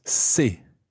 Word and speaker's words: ces: And the plural version is c'est, C-E-S, c'est. – C'est. – Now, be careful not to confuse c'est, C-E-S, with c'est, which sounds identical but is spelt S-E-S.